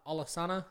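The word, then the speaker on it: Alesana: Alisana, I think I'm pronouncing that right. – Yeah. – Alisana.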